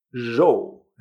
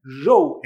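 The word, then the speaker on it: zhou (肉): Así que es una vibración suave, sin la E -r nuestra, sino más bien zhou. ¿eh? Zhou en chino significa carne. Así que si quieres comer algo en China, comer algo de carne, asegúrate de pronunciar bien esta palabra.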